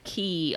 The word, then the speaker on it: Cay: Is it key or K? – I always get them confused. But anyway, I did the race on the island. – I believe they pronounce it key on key. – The ship. – Okay. – Well, I'm actually wearing the t-shirt, and it's spelled C-A-Y.